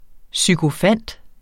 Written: sykofant

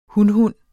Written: hunhund

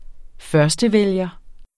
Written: førstevælger

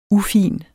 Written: ufin

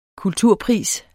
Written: kulturpris

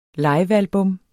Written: livealbum

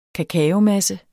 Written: kakaomasse

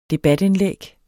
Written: debatindlæg

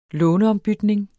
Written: låneombytning

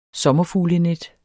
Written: sommerfuglenet

